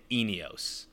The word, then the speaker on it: ENEOS: Uh, I was like, so is it Eneos? Enios? Like I, I didn't know what it was, but it's it's how it's spelled. E Neos. Like that's it. Eneos.